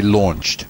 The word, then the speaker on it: launch: While we were there, they launched. While we were there, they launched. – While we were there, they launched. Смотрите, launch – запускать. То есть AU всегда читается как O.